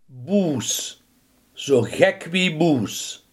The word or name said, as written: uitgelaten